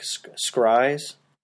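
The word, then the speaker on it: Schyrai: Scryes, that's how it's pronounced, S-C-H-Y-R-A-I, I think it's spelled.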